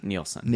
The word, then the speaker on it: Nielsen: Or is it Nelson or Nielsen? Nielsen. Nielsen. Okay, good. I said it right the first time,